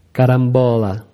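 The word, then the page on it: carambola: Brazilian Portuguese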